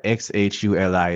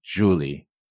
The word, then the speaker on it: Xhuli: And today I learned that XHULI is pronounced Xhuli. I had Xhuli in my head, That's right. – Apparently I was wrong.